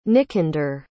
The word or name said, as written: nicander